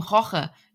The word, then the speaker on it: Jorge: Das nennt sich wohl Team Jorge. Nee, Team Roche. Jorge. So steht es auf meinem Zettel, aber ausgesprochen heißt es Team Roche.